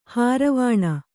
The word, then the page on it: ಹಾರಿವಾಣ: kannada